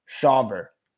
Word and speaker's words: Shawver: AJ Smith Shauver, I think is his last name, is how you pronounce it. S-H-A-W-V-E-R.